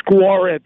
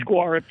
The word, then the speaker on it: Gwareb: Yeah, yeah, it's Guarib. It's Guarib. G W A R E B.